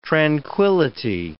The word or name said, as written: tranquillity